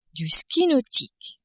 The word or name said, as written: ski nautique